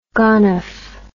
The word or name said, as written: gonef